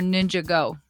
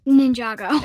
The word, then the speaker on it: Ninjago: Is it Ninjago or Ninjago? – Ninjago. – It is Ninjago.